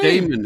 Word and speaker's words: Damon: Damon. It's Damon Albarn. – Damon. – I think. Yeah. Not Damien. – Damon Albarn. Yeah. It's not Damien.